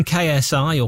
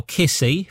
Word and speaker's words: KSI: Holiday from KSI, or Kissy, if you want to pronounce his name incorrectly.